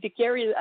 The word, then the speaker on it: Ducat: For people who can pronounce it, Marie-Ève Ducat or Marie-Ève Ducary, as you pronounced it in English.